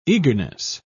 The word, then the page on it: eagerness: British English